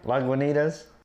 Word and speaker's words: Lagunitas: Lagunitas? – Yeah, Lagunitas.